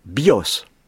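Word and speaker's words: bios (βιός): Eh bien, un mot archaïque grec, si on ne sait pas ça, désigne l'arc par bios, avec l'accent sur la dernière syllabe. Mm-hmm. Alors que le mot vie, c'est l'accent sur la première syllabe.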